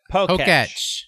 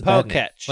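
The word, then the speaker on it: Pokétch: How do you say it? – Poketch. – Poketch. That's a Poketch. – Like Etch a Sketch.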